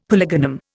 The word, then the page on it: polygonum: Indian